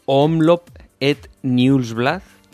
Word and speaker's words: Omloop Het Nieuwsblad: Vamos a empezar con estos nombres de clásicas que no somos capaces de pronunciar por estas latitudes, como la Omloop Het Nieuwsblad. – Muy bien, muy bien. – No me ha salido mal, ¿verdad? – Te ha salido bien.